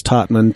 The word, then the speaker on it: Totman: Last name's Totman, T-O-T-M-A-N.